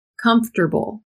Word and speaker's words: comfortable: Comfortable. This one's tough. We pronounce it as if it has three syllables. Comfortable. Comfortable. Comfortable. Although it is spelled comfortable, but do not pronounce it like that.